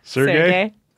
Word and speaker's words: Sergey: How do you say it? – Sergey. – Sergey? – Sergey and that. Igor. – Did you ask him? – No, that's how you pronounce those names.